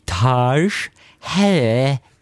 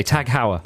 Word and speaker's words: Tag Heuer: And I used to pronounce it Taj Heuer. – Like the Taj Mahal. – Taj Heuer. No, Tag Heuer is what I used to say. Tag Heuer. – Tag Heuer.